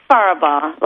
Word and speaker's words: Faribault: It's Faribault, like far away.